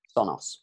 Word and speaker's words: Sonos: Sonos.